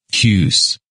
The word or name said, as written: Hughes